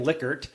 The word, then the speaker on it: Likert: Just that someone knew him, the Likert scale, I mean, which everyone pronounces his name wrong, pretty much. I hope I got it right in saying Likert. – That's right, yeah.